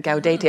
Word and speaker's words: Gaudete: Gaudete. Am I saying that right? – Yes, you're right.